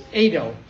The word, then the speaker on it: eido: It's the word, I don't know if I'm pronouncing it correctly, but it's eido. It's E-I-D-O, E-I-D-O.